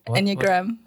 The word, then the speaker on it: Enneagram: Enneagram.